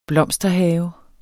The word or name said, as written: blomsterhave